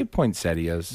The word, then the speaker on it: poinsettias: Did you just say poinsettias? – That's how they said it in the movie, not my personal opinion. I have learned since then that is actually how you pronounce it.